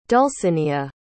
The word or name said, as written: Dulcinia